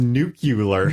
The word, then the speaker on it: nuclear: It's pronounced nuclear. – Nuclear.